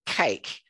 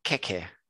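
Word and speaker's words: queque: But they don't pronounce it cake. They pronounce it keke.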